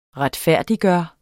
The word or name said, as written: retfærdiggøre